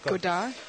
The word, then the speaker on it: Godard: And I'm glad I can pronounce their names, because that other guy's name I can never pronounce, the one Jean-Luc Godard. – That's it. You don't pronounce the D at the end? – That's correct. – How come? – Because it's French.